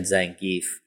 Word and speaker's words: Zangief: Actually, it's Zangief, but I've always said Zangief.